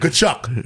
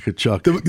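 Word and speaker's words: Tkachuk: Kachuk. – Kachuk. You can't even, and if you see it, you'd never forget how to pronounce it.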